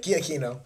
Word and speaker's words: Giacchino: No, it's close to term, it's not Giacchino level, obviously, but, it's close to it, or How do you say it? – Giacchino. – Giacchino.